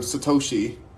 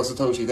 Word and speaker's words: Satoshi: Sakotis, um, Takoshi Sakanam. Uh, yes, um, Satoshi. There you go, Satoshi.